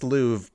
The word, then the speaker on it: Louvre: It's pronounced Louvre, Ben.